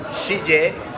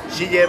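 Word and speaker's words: Xiye: pero se escribe e, X-I-Y-E, Shille, si no me equivoco en la pronunciación, Shille vas Bastira o Bastire o Basti,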